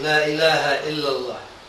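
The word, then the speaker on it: La Ilaha Illallah (لا إله إلا الله): Is that how to say it? – Yes, La Ilaha Illallah.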